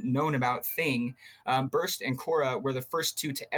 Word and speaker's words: Qora: Yeah, Quora. So it's spelled C or Q-O-R-A. A lot of people get it confused with Q-U-O-R-A, whatever that is, like some communications platform.